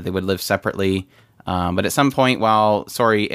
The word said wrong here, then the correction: Sorey: and or Sorey, I'm sorry, I'm saying his name wrong again. I'm going to get in trouble for that. Sorey and uh, Mikhailo they love exploring ruins, and at some point they run into uh, Alicia Dif- Diftad.